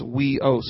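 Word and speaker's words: weos (υἱός): So the second one is huios. Huios. That's the Greek word. It's spelled huios. And I put in parentheses next to it as weos, so I could remember how to actually say it.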